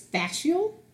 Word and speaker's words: fascial: It's fascial, F-A-S-C-I-A-L, stretch therapy.